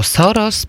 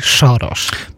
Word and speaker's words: Soros: Niektórzy mówią Soros, pan mówi Soros. – Tak, bo powinno się to wymawiać z węgierska.